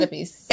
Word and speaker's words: Oedipus: Oedipus.